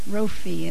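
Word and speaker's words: Rophe: Jophi. Rophi. Sorry. I'm thinking of next week. Rophi, it's the PH is an F sound. So, your Jehovah Rophi, he is the one that heals you.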